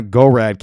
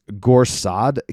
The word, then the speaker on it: Gorsad: Jake Shields got it wrong. It's not Gorad Kiev. It's Gorsad Kiev is how you spell it. G-O-R-S-A-D.